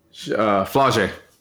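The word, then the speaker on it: Flau'jae: Flaje. – Flaje. Spell it. – F-L-A-U apostrophe J-A-E.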